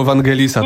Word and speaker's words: Vangelisa: Pisze się przez V, czyta przez W. – Nie, Ciemno. Pan Tomasz wygrywa. Gratuluję serdecznie. – Chodziło o Vangelisa.